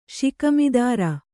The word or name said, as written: ಷಿಕ್ಮಿದಾರ